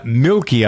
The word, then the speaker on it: Milke: Her name is Deborah Milkey, I believe. That's how you pronounce her name, M-I-L-K-E.